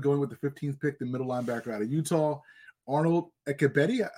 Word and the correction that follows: Ebiketie: Ebiketie, is that how you pronounce it? – From Ebiketie. – Penn State. Ebiketie, the edge rusher from Penn State, formerly of Temple.